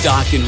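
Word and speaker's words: Dokken: You know, I like how it says in parentheses behind the word Dockin' that it rhymes with rockin'. Like, we're going to hire a part-timer that just might not know that. It's Dockin' right there, of course.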